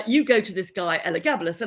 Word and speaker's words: Elagabalus: Yeah, and I got to pronounce it right, Elagabalus.